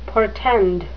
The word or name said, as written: portend